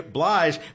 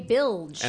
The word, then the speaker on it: Blige: Blige. What did Leland say? – Mary J. Bilge.